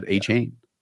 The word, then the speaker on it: Achane: asked him how to pronounce his name, and he said Achane.